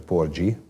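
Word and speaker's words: Porgy: hogy a csomó kritikusunk azt mondta, hogy Porgi, tehát hogy sikerült a címet is jól kiejteni, de mindenki szakértő volt a témában.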